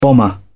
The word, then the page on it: bomber: British English